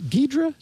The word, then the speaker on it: Ghidra: Ghidra? – Yeah, that's that reverse engineering tool.